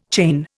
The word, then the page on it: chain: Indian